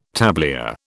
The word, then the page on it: tablier: British English